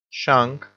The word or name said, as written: shang